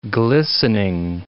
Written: glistening

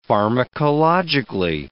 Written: pharmacologically

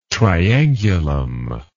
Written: triangulum

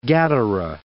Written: Gadara

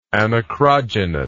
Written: anacrogynous

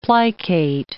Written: plicate

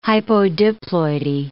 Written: hypodiploidy

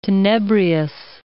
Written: tenebrious

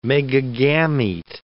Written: megagamete

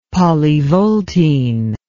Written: polyvoltine

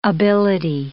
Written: ability

